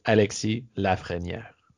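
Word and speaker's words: Alexis Lafrenière: So it's Alexis Lafreniere. – Okay, if it's if it's true French, you can get away with saying Lafreniere and you'll be okay.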